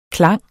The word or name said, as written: klang